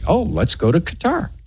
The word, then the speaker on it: Qatar: And I just want to credit Zach Seegers back there for saying Qatar right, because very few people do.